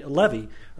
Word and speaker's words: Levi: Levi are descendants of the family, Levi is how it's pronounced, the Levitical tribe, the Levitical family.